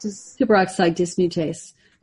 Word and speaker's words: superoxide dismutase: Superoxide dismutase.